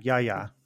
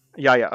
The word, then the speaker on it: Yahya: Yahan Abdul-Mateen. I'm not sure if I'm pronouncing his name correctly. I apologize. – Yahya Abdul-Mateen. Yeah. Yahya. – Yahya. Okay.